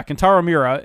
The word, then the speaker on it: Kentaro Miura: Kentaro Miura, and he recently died.